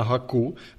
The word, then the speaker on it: Haku: Tak, Jakub teda, jak jsem říkal, on je z designerského studia na Haku. Říkal jsi, že se to čte krátce. – Čte se to krátce, promiň, že ti do toho skáču.